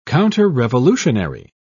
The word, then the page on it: counterrevolutionary: British English